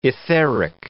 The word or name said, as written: etheric